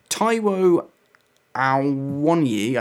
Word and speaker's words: Taiwo Awoniyi: Uh, then you've got Freuler and Yates in the middle, and then a front three of Gibbs White, Taiwo Awoniyi, I think is how you say it.